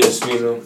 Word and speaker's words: Smino: Dave East, G Herbo, um, uh, it's a couple niggas like Smino. I don't even know if I'm pronouncing that joint right. – Yeah, Smino. Yeah, Smino.